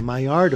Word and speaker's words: Maillard: The Maillard effect. – Yeah, it's spelt a little weird. It's M-A-I-L-L-A-R-D. Maillard. – Maillard. – Well, the L's are silent, okay?